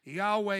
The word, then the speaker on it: Yahweh: Yod-Heh-Vav-Heh. Yahweh is the best way we understand to pronounce it.